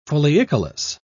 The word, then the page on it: foliicolous: British English